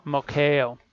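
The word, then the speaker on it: moichaō: Mokeo? – Mokeo.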